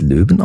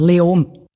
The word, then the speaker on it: Leoben: Spricht man es Löwen aus? Wie spricht man das überhaupt aus? – Eigentlich fast kein Konsonant. Leom.